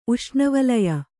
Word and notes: ಉಷ್ಣವಲಯ: uṣṇavalaya

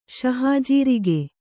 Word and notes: ಶಹಾಜೀರಿಗೆ: śahājīrige

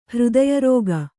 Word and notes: ಹೃದಯರೋಗ: Kannada

